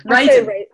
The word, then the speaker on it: Radun: Radun.